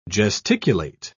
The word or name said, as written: gesticulate